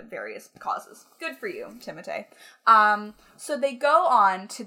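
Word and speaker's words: Timothée: They go on to talk about Timothy Chalamet, or Timotei, as his name is properly pronounced, which is so pretentious.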